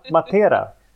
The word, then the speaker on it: Matera: Då är det tvärtom här, nu är det Matera. – Ja, precis. – Ja, okej. Okay. Nu var det den andra. Nu var det betoning på andra vokalen.